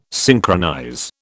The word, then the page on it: synchronize: British English